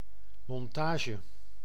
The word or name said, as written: assembly